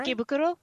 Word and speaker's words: Ikebukuro: Ikebukuro, I think is the name of it. Did I say that right? – Ikebukuro? – Yes.